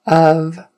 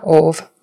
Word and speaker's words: of: Of, not of, of, rhymes with dove, glove.